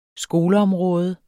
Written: skoleområde

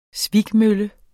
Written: svikmølle

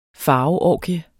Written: farveorgie

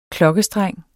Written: KLOKKESTRENG

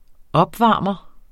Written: opvarmer

